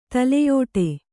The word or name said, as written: ತಲೆಯುಡೆ